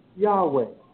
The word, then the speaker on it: Yahweh: And also, if you look in the Encyclopedia Judea, volume number 7, page 679, the pronunciation of Yahweh, Y-H-V-A, is pronounced Yahweh.